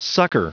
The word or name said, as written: succor